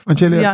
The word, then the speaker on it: Angelica: Is het nou Angelica? Zeg ik dat goed? – Angelica ja, Cecilia.